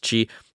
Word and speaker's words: Qi: Now, if you're not as familiar with it, Qi 2 is the second generation of Qi wireless charging, which you've typically seen like the letter Q and I, but it's pronounced Qi.